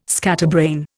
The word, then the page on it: scatterbrain: Indian